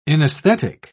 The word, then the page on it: inaesthetic: British English